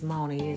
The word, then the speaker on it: Miouxnie: It's Moni. It's Moni Uncut. And it's spelled M-I-O-U-X-N-I-E.